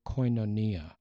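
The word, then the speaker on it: koinonia (κοινωνία): The Greek word is the word koinonia. It's spelled K-O-I-N-O-N-I-A.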